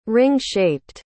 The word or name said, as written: ring-shaped